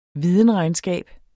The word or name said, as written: videnregnskab